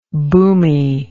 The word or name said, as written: boomie